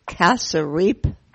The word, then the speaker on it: cassareep: Kasarip. – Kasarip. – Kasarip. – Spell it. – C-A-S-S-A-R-E-E-P.